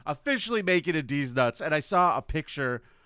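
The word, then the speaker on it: Dez Nutz: And he changed his name to D's Nuts, spelled D-E-Z. Dash N-U-T-Z. – He spelled his joke name wrong and it became Des Nuts. And everyone was like, why are you Des Nuts? He said, no, it's D's Nuts.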